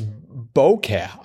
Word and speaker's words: bokeh: Well, I loved five across, out of focus effect in photography from the Japanese for blur, and it's bokeh. I've never heard that pronounced. It's spelled B-O-K-E-H.